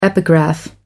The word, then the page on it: epigraph: American English